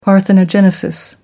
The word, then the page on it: parthenogenesis: American English